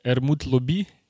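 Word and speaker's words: Hermutt Lobby: Э, это такие чуваки, Хермут Лобби они называются. Пришлю, как правильно пишется. По-французски они Эрмут Лобби, но так они как Хермут Лобби пишутся.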